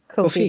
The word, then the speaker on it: Ko-fi: Ko-fi. Because it's meant to kind of rhyme with both coffee. – Coffee. – And no fee.